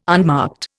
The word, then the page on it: unmarked: Indian